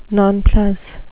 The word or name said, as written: nonplus